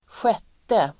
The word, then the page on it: sjätte: [²sj'et:e]